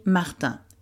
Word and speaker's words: Martin: Well, ça s'écrit Martin, M-A-R-T-I-N.